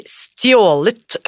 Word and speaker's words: Stjålet fokus: How do how do you say the title in Norwegian? so so the, the, the, title in Norwegian would be "Stjålet fokus," which is exactly like word for word.